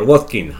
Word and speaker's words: Watkins: Ollie Watkins, sí señor, Watkins.